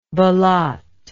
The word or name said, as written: belote